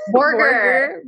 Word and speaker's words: Borger: Is that how you say your name? Borger. – Borger. I'm so sorry.